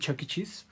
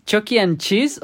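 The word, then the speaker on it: Chuck E. Cheese: ¿Sí, es Chucky and Cheese o Chucky e Cheese? Chucky Cheese. Chuck. Es Chuck y e. Cheese. O sea, I de E.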